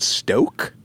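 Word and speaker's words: Stōk: We've got, how do you think we pronounce this? Is it Stoke? – Yeah.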